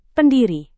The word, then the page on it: pendiri: Indonesian